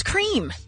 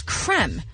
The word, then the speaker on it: Creme: It's not pronounced cream. – What? – Cadbury came out yesterday and said, no, it's pronounced as creme.